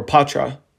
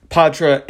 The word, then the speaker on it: Poitras: So with that said, John Beecher and Matthew Poitras, or Patra, I was saying his name wrong the other day, Matthew Patra. Between Patra and Beecher,